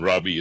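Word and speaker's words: Robby: And Robbie is spelt with a Y, not an I-E.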